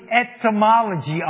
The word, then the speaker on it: etymology: Do not confuse that with entomology. Entomology is the study of bugs. Etymology is the study of words.